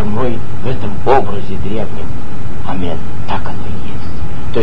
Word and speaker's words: амен: Что значит амен? Амен — это слово как бы святое, правильно? Оно произносится после благословения. Вообще-то надо сказать амен.